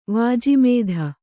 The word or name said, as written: ವಾಜಿಮೇಧ